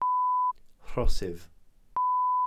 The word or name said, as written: rhosydd